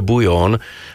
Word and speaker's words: Bouillon: Opäť domáca hudba v playliste FM Nylon Union a Off The Plot a predtým Bouillon, alebo ak chcete, alebo vraj sa to vyslovuje Bouillon a skladba Francis Ford featuring L.